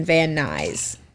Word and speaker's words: Van Nuys: Bruce had a six-figure job as an electrical engineer as military contractor in Van Nuys.